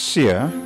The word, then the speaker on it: Sia: okay, so the I's pronounce E, so it's Sia.